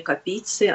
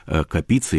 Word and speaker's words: Капица: Интересно, а известно что-то о возможном знакомстве Капицы или Капицы, как делают ударение в его фамилии, и Набокова?